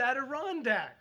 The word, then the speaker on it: Adirondack: And I had to learn, as I got those looks on people's faces, I had to ask after the service, how do you say that word? It's Adirondack.